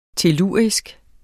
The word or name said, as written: tellurisk